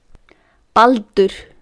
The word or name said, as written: Baldur